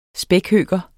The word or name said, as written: spækhøker